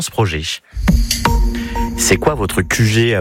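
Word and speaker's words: Kujé: En Ille-et-Vilaine, vous pouvez en découvrir d'autres grâce à QG. Ça s'écrit K-U-J-E accent aigu.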